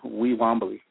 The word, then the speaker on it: Wiwambli: One day she stopped me and she said, grandson, I will give you the name Wee Wombly. And that's spelled W-I-W-A-M-B-L-I. The first syllable is Wee, of course, and that stands for the sun. And Wombly is the eagle.